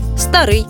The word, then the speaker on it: старий: До речі, у більшості двоскладових прикметників наголос падає на закінчення. Новий, старий, гіркий, тонкий, товстий, тісний, вузький тощо.